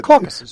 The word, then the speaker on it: Caucasus: it's the Caucasus. Is that how you say it? The mountains there? – The Caucasus.